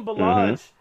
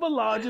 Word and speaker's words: Ballage: Kalen Ballage is what does Ballage rhyme with? Garbage. So it's close.